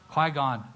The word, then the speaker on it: Qui-Gon: Ki-Kwong? Did I say that right? Qui-Gon. Oh, forgive me.